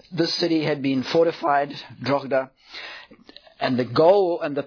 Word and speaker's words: Drogheda: I don't know exactly how you pronounce that. It's got the H in there somehow. Drogheda. So that's how I'm going to pronounce it.